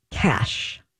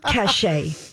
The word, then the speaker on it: cache: First of all, how do you pronounce C-A-C-H-E? – C-A-C-H-E. Chachi? What? – No, it's... cash. Cash or cachet. – Oh, cachet. Sorry. – But it is not cachet. You pronounce it Cash. – Oh, cash. – No matter. – I'm a visual learner, and I need to like see it. Sorry. – Okay. So no matter what you're talking about, because some people say, "Oh, she has cachet." That's a whole other word. No, but it's pronounced cash.